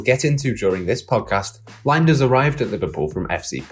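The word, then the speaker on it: Lijnders: Pep Linders, and yes, that's how you pronounce it, is one of Klopp's right-hand men.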